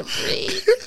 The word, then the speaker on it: breathe: Breath. – It's that a breathe. – Oh, my Mook, God. – It's pronounced breathe.